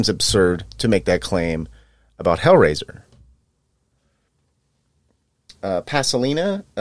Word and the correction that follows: Pasolini: uh, uh, Pasolini, Paolo Pasolini. I'm bad with my Italian pronunciations. A lot of vowels. It's hard for me to pronounce.